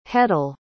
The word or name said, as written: hetal